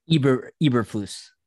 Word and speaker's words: Eberflus: Eberflus. – Eberflus, thank you. Who, I don't know if we discussed this or not, but when Brian Daboll was with the Cleveland Browns, Eberflus was also part of that system.